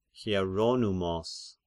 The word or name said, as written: Hieronymos